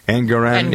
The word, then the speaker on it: Engoron: Yeah, the judge, and the his name is Engoron, I think. Is that how you pronounce his name? Engoron. – Yeah.